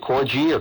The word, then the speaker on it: corgi: Is it corgi or corgi? With a hard G or a soft G, did she say there? I can't. Hard G, corgi. Well, it's a little tiny dog.